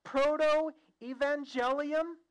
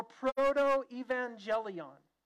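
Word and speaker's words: protoevangelium: This promise in Genesis 3, verses 14 and 15, when we talk about terms of theology, the word is pronounced proto-evangelium or proto-evangelion.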